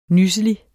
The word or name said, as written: NYSSELIGERE